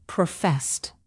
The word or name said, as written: professed